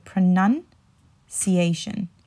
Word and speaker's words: pronunciation: So the way you would say is, remember there's a nun in the middle, right? So pronunciation. I'll say that again. Pronunciation. Pronunciation. So you want to put the nun instead of the noun. So if I say it as a verb, I would say pronounce, right? Pronounce. But as a noun, I would say as pronunciation, pronunciation.